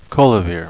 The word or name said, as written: colavaere